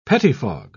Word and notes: pettifog: British English

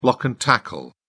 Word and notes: block and tackle: British English

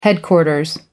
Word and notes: headquarters: American English

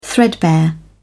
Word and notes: threadbare: British English

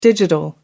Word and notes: digital: American English

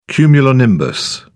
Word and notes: cumulonimbus: British English

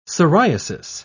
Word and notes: siriasis: British English

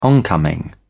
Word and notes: oncoming: British English

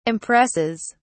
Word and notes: impresses: British English

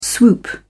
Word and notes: swoop: British English

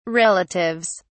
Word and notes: relatives: British English

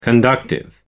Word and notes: conductive: American English

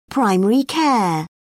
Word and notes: primary care: British English

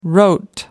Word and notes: wrote: American English

